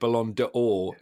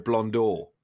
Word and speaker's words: Ballon d'Or: That's almost as bad as the grief I get for pronouncing it Ballon d'Or instead of Blonde d'Or. – Ballon d'Or, do you say? – Ballon d'Or. Ballon d'Or. It's got apostrophe in it.